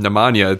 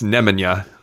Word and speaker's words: Nemanja: And I mean, we were even saying it wrong with Nemanja. It's Nemanja.